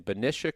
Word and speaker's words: Benicia: And one of the last survivors from that ship is our guest today in Benicia, California.